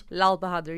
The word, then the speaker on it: Lalbahadur: Lalbahadur, yeah.